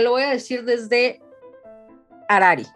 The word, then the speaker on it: Harari: Dinos cómo se pronuncia. – Harari, porque H es muda.